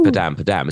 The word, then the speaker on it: padam padam: I just like to make it rhyme with madam. So, but it's padam padam, isn't it? Like a heartbeat, apparently.